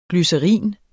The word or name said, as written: GLYCERIN